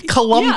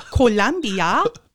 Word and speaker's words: Colombia: Colombia. Yeah, it's like Colombia. You actually say it right.